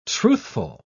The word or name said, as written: truthful